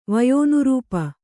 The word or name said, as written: ವಯೋನುರೂಪ